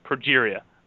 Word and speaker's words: progeria: A form of progeria.